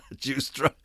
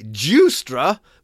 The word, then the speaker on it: Giustra: That's J... No, I'm sorry, not J-E-W. Giustra. That's G... I-U-S-T-R-A, Giustra, but it's obviously pronounced J-E-W, Giustra, a mining financier, has donated $31.3 million to the foundation run by former President Bill Clinton.